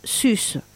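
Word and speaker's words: sus (σῦς): Il est piquant de noter que pour l'université française, le mot grec porc se prononce sus.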